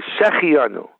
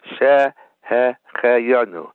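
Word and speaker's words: Shehecheyanu (שהחיינו): They say Shehecheyanu. They skip the hey. It's a different meaning. There has to be three segols. A segol is a vowel. The segol sound is an eh sound. It should sound like this. Shehecheyanu. That he caused us to live, not Shehecheyanu. That's a different word. It has to be separately pronounced, Shehecheyanu.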